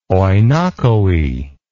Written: oinochoe

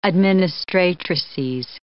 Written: administratrices

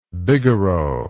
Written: bigarreau

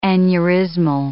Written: aneurysmal

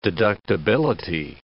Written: deductibility

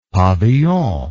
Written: pavillon

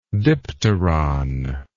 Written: dipteron

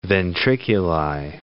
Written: ventriculi

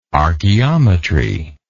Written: archaeometry

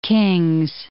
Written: Kings Canyon National Park

